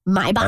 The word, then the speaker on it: Maybach: And apparently you're supposed to pronounce it Maybach. – My back. – As in, like, my back hurt.